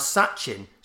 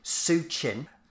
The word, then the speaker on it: Sachin: To stand in front of the masses in India and pronounce Sachin, Suchin, he's made him sound Chinese.